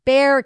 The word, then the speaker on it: Bexar: Oh, hey, Carrie just, she says it's pronounced Bear County.